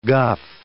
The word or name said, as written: guff